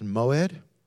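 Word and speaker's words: Moed (מוֹעֵד): It's pronounced Moed.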